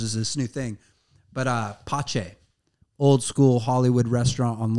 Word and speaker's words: Pace: They have Pache. – It's like P-A-C-E. Pace is like how it's spelled.